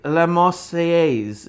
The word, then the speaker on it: La Marseillaise: So he would play La Marseillaise. Is that how it's pronounced? – Yeah.